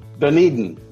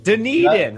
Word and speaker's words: Dunedin: Wait, I said that wrong. How do you pronounce it? – Dunedin. – Dunedin, yeah. Right.